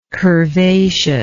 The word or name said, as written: curvaceous